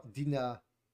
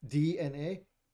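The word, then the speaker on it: DeNA: Dina. It's spelled Dina, but it's apparently said DNA.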